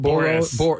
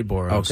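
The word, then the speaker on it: Boras: Boros. – I say Boros.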